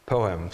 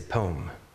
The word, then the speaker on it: poem: I'm from the East Coast and I mispronounced poem so many times people made fun of me. I would say poem, but it's poem. It's actually, it's two syllables.